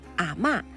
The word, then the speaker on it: 阿嬷: 广东技术师范大学的教授林伦建议大家提到这部电影的时候要读的是四声阿嬷，指的就是奶奶。